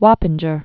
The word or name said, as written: Wappinger